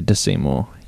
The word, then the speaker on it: Da Seymour: It's like Da Vinci, so you say Da Seymour.